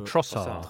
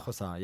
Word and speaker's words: Trossard: Yeah, we don't pronounce the D, though. It's a bit like Giroud. You don't say the D. – So Trossard. – Trossard, yeah. You stop at the R.